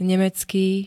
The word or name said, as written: nemecký